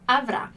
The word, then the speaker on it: avrà: Como o futuro, como a gente está vendo aqui, a nossa sílaba tônica, então onde cai a intensidade de quando a gente fala a palavra, é na última letra, na última sílaba, né?